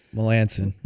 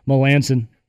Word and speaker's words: Melancon: Melancon. – Well, butchered that one. Melancon.